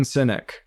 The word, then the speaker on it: Sinek: Simon Sinek.